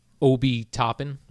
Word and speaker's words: Obi Toppin: But I must say, and I know very little about this gentleman, Obi Toppin.